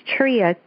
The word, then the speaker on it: Tria: So I spell it with an I, L-I-N-D-A, and last name is Tria, T-R-I-A.